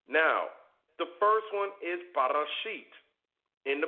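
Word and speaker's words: Bereshit: Genesis is produced, or it is pronounced as Barashit.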